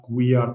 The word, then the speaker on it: guía: Ella está leyendo un prospecto o una guía turística. – Guía. This is pronounced guía turística. Okay? Guía. So the U is silent.